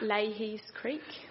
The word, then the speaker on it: Lahey's: Leahy's Creek. – It's spelled L-A-H-E-Y-S.